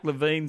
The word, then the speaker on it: Levine: Mark Levine, thank you. And it's spelt L-E-V-I-N-E, as in, you know, grapevine, L-E-V-I-N-E.